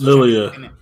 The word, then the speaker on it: Lilia: I can't ever pronounce Lilia.